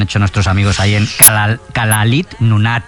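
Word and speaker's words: Kalaallit Nunaat: Nada, allí cuando juntan las dos L le ponen una S y una L. Kalalit Nunat.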